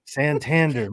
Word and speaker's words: Santander: Santander.